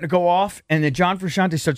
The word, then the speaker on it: John Frusciante: I know lots of people could do this, but there's a cool video of John Frusciante. Is that how you say his name? The Red Hot Chili Peppers guitarist. – That sounds right.